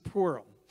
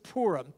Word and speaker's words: Purim: It's called the Feast of Purim, P-U-R-I-M, but it's spelled like P-O-O-R, Pur. So Purim, the Feast of Purim, what is that?